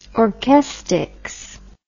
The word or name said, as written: orchestics